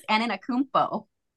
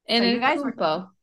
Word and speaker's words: Antetokounmpo: Giannis Antetokounmpo. – That's close. It's Giannis Antetokounmpo. – Antetokounmpo. Okay. Antetokounmpo.